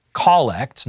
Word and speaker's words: collect: Now, it's pronounced collect, not collect, and that's not a Canadian pronunciation, it's a Latin pronunciation, meaning to gather.